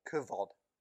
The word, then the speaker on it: kavod: The Hebrew word for glory is kavod, or kavod, as it's supposed to be pronounced.